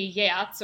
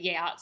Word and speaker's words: Geats: My friend Catherine, who was on my last Beowulf episode and is an actual Old English scholar, told me to pronounce it Geats, so I'll say Geats.